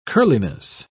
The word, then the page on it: curliness: British English